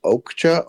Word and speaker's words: Okja: I think it's pronounced Okja, O-K-J-A.